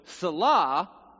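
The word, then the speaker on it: selah: So salah is the Hebrew pronunciation of a musical term that nobody knows what it's all about.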